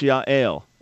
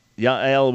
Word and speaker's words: Yael: In Hebrew, that's Yael. It's a nice Hebrew name. One of my closest friends in Israel has a daughter by that name, Yael. So Yael would be how to pronounce it.